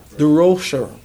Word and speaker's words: Durocher: Durocher. See? Good. I just showed you my ignorance, but God restores. Praise God. He worked with Willie Mays in Willie Mays' early days, and they tell me that Durocher was a rough guy in his young days.